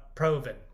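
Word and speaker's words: Provan: Was it Ian, he writes a lot, and I don't know how to pronounce his last name. Proven? – Proven. Ian Proven.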